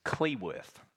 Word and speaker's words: Cleworth: My surname is Cleworth, C-L-E, Worth.